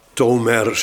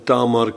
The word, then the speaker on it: Tamar: Nou ja, Tomer zegt men dan, maar met die uitspraak hou ik me niet bezig. Het is Tamar en Tamar kennen we als de naam van een prinses uit het huis van David.